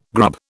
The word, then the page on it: grub: British English